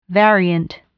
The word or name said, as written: variant